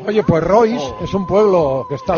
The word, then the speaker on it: Rois: Pero se escribe decir. Royce, R-O-I-S. – Sí. – Vale, da igual. – Ese en francés, Roa.